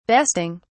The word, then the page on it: besting: British English